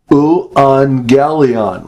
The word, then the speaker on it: euangelion: When you get two gammas together like that, the first one is pronounced like an N, and the second one has the G. So it's euangelion.